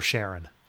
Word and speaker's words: Charon: I had a really good time with an obol for Charon. That's how you say it, right? – Well done.